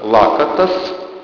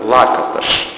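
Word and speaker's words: Lakatos: Дело в том, что это венгерская фамилия, по-венгерски читается Лакаташ. Просто если правильно прочесть, как вы читают венгры, там на конце звук «ш». Просто по правилам чтения в венгерском.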